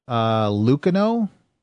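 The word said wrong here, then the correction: Luceno: James Lucino.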